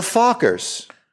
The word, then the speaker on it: Fockers: The Fockers, pronounced like the Gaylord's last name.